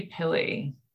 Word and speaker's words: Pilly: She has a new book and tarot deck out, and it's called The American Renaissance Tarot, also illustrated by Celeste Pile. Did I say that right? – It's actually Pilly.